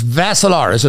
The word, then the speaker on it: Vassilaris: That's Dimitri with three I's, D-I-M-I-T-R-I. The last name is Vassilaris. It rhymes with Assilaris, except you had a V.